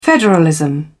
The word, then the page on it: federalism: British English